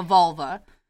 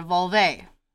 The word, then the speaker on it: vulva: You guys, I understand that last episode I mispronounced what a vulva. I kept calling it a vulve.